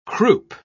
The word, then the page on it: croupe: British English